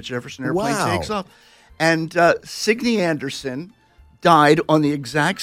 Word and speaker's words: Signe: It was it's a Danish name, and they pronounce it Signe.